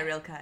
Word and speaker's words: Rilke: Rilke, is that how you say it? Rilke? – Yeah, Rilke, yeah.